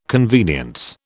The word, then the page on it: convenience: British English